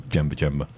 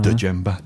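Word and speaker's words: Djemba: But yeah, I don't think we're challenging the grabbing Twanzebe for strength just yet on the strength of Jemba. – Yeah, not a good move. – Why the silent D? Why it's not Dejemba? – Dejemba.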